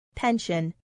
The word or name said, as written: pension